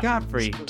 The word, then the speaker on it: Godfrey: I have been instructed to say that I'm not allowed to say that it's pronounced in the traditional King's English of G-O-D-F-R. – Godfrey.